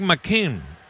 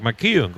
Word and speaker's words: McKeon: igualando a Jack McKean, o Jack McKeon, como ustedes quieran pronunciar.